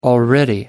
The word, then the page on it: already: American English